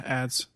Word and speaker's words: adzes: It goes into like ads. Is that how you say it? The ADZES. – Yeah, ads.